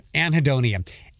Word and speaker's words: anhedonia: Anhedonia. And I'm over-pronunciating that. Anhedonia. A-N-H-E-D-O-N-I-A.